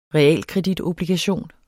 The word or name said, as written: realkreditobligation